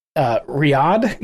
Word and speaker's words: Riyadh: During a landmark visit to Saudi Arabia's capital, Riyadh.